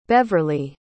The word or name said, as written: Beverley